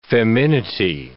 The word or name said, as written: feminity